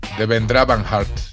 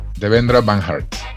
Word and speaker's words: Devendra Banhart: Daniel Kien, eh, Debra Benhart. – De Van Hart. – Van Hart. ¿Tú, tú, de Devendra Van Hart.